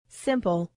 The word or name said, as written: simple